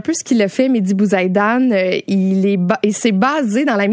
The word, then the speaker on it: Mehdi Bouzaïdan: Mais parle-nous de Mehdi Bouzaïdan, j'espère que je le prononce comme il faut. – C'est parfait, Mehdi Bouzaïdan, qui a présenté cette semaine son spectacle Demain, qui était présenté à la 5e salle de la Place des Arts.